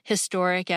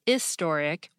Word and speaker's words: historic: Although he pointed out that British people pronounce historic as historic without the H, and some Americans do too, he argued that the majority of Americans pronounce the H, historic, so the correct forms in most places in the United States are ahistoric and ahistorical.